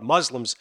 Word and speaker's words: Muslim: There is a great comedic moment, though, by the way, when he's talking about Muslims. And Rami's friend, Muhammad, goes, hey, by the way, it's a soft S. It's pronounced Muslim, just like pussy.